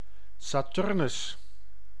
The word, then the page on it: Saturnus: Dutch